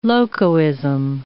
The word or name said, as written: locoism